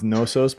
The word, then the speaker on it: Knossos: No, it's uh, Knossos. I think it's just pronounced Nosos, but it's Knossos in Crete in uh, in Greece.